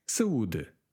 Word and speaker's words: saúde: Saúde. E saúde. Notice a little hat on top of the U, diacritic. – Mm-hmm. – That means you emphasize that particular syllable.